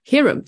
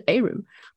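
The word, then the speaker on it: heirloom: 不管是在我们看到的很多咖啡烘焙商外包装的豆袋上会写 Arum，但是很多人会念成 Herum，但其实是 Arum。关于这个原生种的问题，其中有一个的这个中文的释义叫传家宝。所以我其实特别想问一下徐老师，这个 Arum 这个种类到底是特指一个品种还是泛指？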